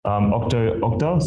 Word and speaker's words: Octo Octa: But is Octo Octa? – Octo Octa. So yeah. – Just, how do you spell that? – OCTA. – Yeah. Oh, Octa.